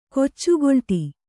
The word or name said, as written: ಕೊಚ್ಚುಗೊಲ್ಟಿ